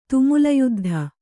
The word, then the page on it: ತುಮುಲಯುದ್ಧ: kannada